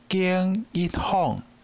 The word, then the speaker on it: 金一凤: 这段特别注意人名，金一凤，伊姓金，吼，风景的金，诶，读音相像吼。金一凤，第二段，自细汉喜爱美术的金一凤，看到田园里的葫芦，就踮顶悬画图做姨娘。